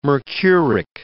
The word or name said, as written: mercuric